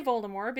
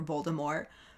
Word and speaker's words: Voldemort: But maybe Ron just doesn't like when people say Voldemort because he finds the silent T at the end to be pretentious as fuck.